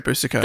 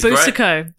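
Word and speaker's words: Boucicault: But that's the difference between you and the great Boosico. – That's right. That's why he's the great Boosico. That's why he's... – That we've all heard of. – Exactly. A household name. And I'm nothing. – Dion Boosico.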